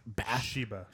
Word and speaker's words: Bathsheba: Yeah, Bathsheba.